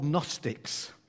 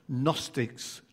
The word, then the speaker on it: Gnostics: And the false teachers were called Gnostics, has a silent G on the front. Gnostics, Gnosticism.